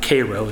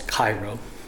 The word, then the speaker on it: Cairo: It's actually pronounced Cairo, as they say. It's called Cairo.